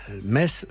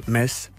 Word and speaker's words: Metz: Metz se prononce Metz, comme voilà. La Metz, effectivement. On ne prononce pas le T.